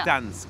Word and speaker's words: Gdańsk: Man spricht nur das D, oder? – Nee, N mit Strich. – Ja. – Nee. Gdańsk.